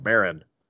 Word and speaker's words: Maron: Maron.